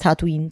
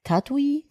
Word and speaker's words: Tatooine: Es heißt Tatooine. Das N spricht man mit, ne? Tatooine? – Tatooine.